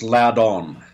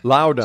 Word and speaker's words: Loudon: It, it's actually pronounced Loudon. Loudon.